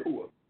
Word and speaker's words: Ruas: Oh, you pronounced it like, oh, so he spelled it R-U-A-S. – It's R-U-A, but it's pronounced Hua.